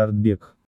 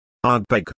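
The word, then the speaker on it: Ardbeg: В предыдущем разделе упомянуты, в порядке появления и, по чистой случайности, в алфавитном, три названия, одно вполне ожидаемо читается Артбек. – Артбек.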